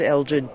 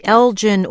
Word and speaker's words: Elgin: We said Elgin, as you heard. Some of you wrote that the correct pronunciation is Elgin. Michelle, I should say, Robert Siegel, our co-host, is a firm believer in Elgin.